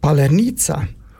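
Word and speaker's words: Паляниця: Palanitsa. – Nicht, ja? Nicht schlecht. – Nicht schlecht. – Sehr gut.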